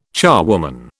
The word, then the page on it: charwoman: British English